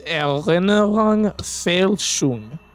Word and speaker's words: Erinnerungsfälschung: It's pronounced, it's Erinnerungsfälschung. Because the two dots above the A make it a long A, so you go A.